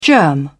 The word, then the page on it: germ: British English